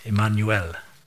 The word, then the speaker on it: Emmanuel: So in the Hebrew, the word Emmanuel is literally pronounced like that, Emmanuel, okay, Emmanuel. And it has five letters.